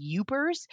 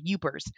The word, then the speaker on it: Yooper: And people who live on the Upper Peninsula, the UP, Upper Peninsula, they call themselves Upers, and they spell it Y-O-O-P-E-R, Upers.